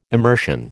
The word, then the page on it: immersion: American English